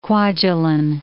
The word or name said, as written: Kwajalein